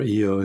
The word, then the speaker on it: Io: Um, it's it, I think what would what would be the killer for humans walking on on Io or EO as it's sometimes pronounced is um, the radiation because yeah.